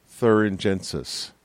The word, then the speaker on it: Bacillus thuringiensis: At the base of this product, I have learned, is Bacillus thuringiensis. That second word is said a bit differently than how it's spelled. Thuringiensis, thuringiensis.